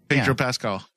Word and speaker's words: Pedro Pascal: Pedro Pascal.